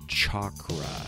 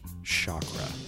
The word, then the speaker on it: chakra: I'm trying to retrain myself to pronounce it in its proper Sanskrit, chakra. My dad said chakra, like a lot of people in the West.